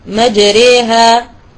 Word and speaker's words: مجريها: ثاني حرف فرعي هي الألف الممالة، الألف التي في كلمة مجريها، وهذا كذلك في رواية حفص، فحفص يميل الألف التي بعد الراء، وهذه هي الحرف الثاني الفرعي.